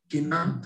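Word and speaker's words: Kina (קנאה): Hakina, was it Kina or Sina? Kina ta'ava v'kavod motzin et adam min ha'olam.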